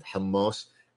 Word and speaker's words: hummus: You know, the ones that everybody would know, shish tawouk and shawarma and hummus. It's hummus. It's hummus. It's not hamas. It's not hamas. It's not hummus. It's hummus. If you don't pronounce it properly, don't effing eat it.